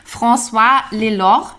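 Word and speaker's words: François Lelord: François Lelor. Ich hoffe, ich habe ihn richtig ausgesprochen. – Es klang super.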